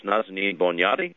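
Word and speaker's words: Nazanin Boniadi: Nazanin's first name is spelled N-A-Z-A-N-I-N. That's N-A-Z-A-N-I-N. Last name Bonjati spelled B-O-N-I-A-D-I. That's B-O-N-I-A-D-I. IADI, pronounced Nazanin Bonjati, born in Tehran, Iran.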